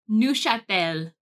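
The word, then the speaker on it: Neufchâtel: So it's Neuchâtel cheese. – How do you even spell that? – It's a N-E-U-F-A.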